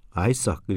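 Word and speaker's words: Isaac: 그러니까 어, 함광호 씨 아이디로 이렇게 사연을 본 아마 ISAAC니까 아이삭 이렇게 되겠죠 발음이.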